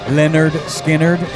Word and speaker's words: Lynyrd Skynyrd: It was called Pronounced Lynyrd Skynyrd in phonetics.